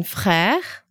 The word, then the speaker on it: frère: As in frère, which is spelled F-R, E accent grave, R-E, which means brother.